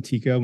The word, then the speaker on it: Tycko: I mean, you can find me on the web, Jonathan Tyko. My last name is spelled T-Y-C-K-O.